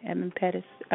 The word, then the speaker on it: Edmund Pettus: I think I'm saying the name right, Edmund Pettus.